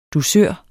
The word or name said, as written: dusør